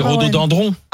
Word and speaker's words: rhododendron: Dindron. – C'est piégeant. – C'est dendron. Alors c'est rhododendron.